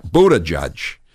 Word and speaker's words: Buttigieg: But how about the mayor of South Bend, the gay guy, Buttigieg, or I don't know how to pronounce his name. – Buttigieg.